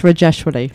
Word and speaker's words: Rajeshwari: It's Rajeshwari.